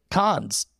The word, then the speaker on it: Cannes: Switching subjects, this actually debuted at, and I always mispronounce it, Cannes. – Oh. – Oh, yeah. – Did I say it right? – Cool.